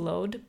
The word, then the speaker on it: Lod: Or I think in Hebrew it's pronounced Lod, but it's in Israel.